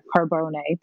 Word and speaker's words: Carbone: But I also am dying to try carbone or carbone, depending on if you want to do the Italian pronunciation.